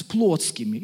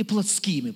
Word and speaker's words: плотскими: Я не мог говорить с вами, братья, как с духовными, но как с плотскими или плотскими, по-разному ударение ставят, да?